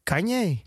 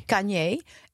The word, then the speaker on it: Kanye: Ik vond, eh, hoe spreek je zijn naam nou echt uit? – Kanye. – Kanye. Eh, Denk ik.